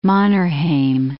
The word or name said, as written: Mannerheim